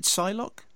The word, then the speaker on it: Psylocke: you pronounce it Psylocke? – Yes.